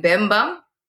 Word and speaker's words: Bemba: So he's speaking in, is it pronounced Bemba? – So Chef is rapping in Bemba, and Tio is singing the hook in Nyanja.